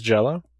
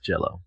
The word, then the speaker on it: Jelly: But it's pronounced Jello? – It is pronounced Jello.